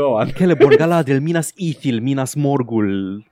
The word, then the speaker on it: Celeborn: Sau cred că e Celeborn? Cred că e Kappa. se pronunță ca un Kappa, C un... uh, în elfa, în elfa lui Tolkien. Nu știu dacă în fix.